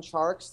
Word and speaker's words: Tjarks: I'm just at Jonathan Tjarks. The last name is spelled TJARKS.